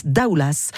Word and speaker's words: Daoulas: On prononce Daoulas. Le S, Daoulas.